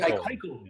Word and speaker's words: Keuchel: Dallas, is it Kuchel? I can't Keuchel. – Pronounce it. Keuchel.